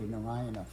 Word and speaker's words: Narayana: Clearly, Narayana, four syllables, and we commonly just shorten it to three.